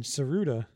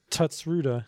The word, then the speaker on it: Tsuruta: Oh, that's how you say it, Tsuruta. – It's Tsuruta. – Yeah. – You gotta drop that T. Yeah. I always mispronounce it as Tutsuruta.